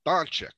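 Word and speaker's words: Dončić: Doncic, and they're playing against each other.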